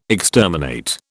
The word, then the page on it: exterminate: British English